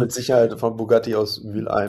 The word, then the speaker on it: Mülheim: Mülheim. – Das H ist ja nicht. Also Mülleim eigentlich. – Wird nicht ausgesprochen.